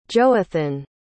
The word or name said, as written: johathan